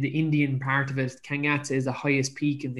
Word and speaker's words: Kangyatse: Kangyatse. So it's spelled K-A-N-G and then a separate word Y-A-T-S-E. So Kangyatse out in India.